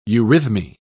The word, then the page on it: eurythmy: British English